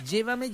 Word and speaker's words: llévame: Porque llévame lleva acento en la primera E, no en la última E.